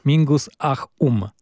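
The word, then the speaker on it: Mingus Ah Um: No i teraz jeszcze tytułem wstępu, bo tak, wiadomo, że z tej najsłynniejszej płyty Mingusa, Mingus, jak się umawiamy do wymowy tego tytułu? Mingus Ahum, czytając to literalnie,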